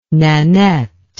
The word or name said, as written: Nanette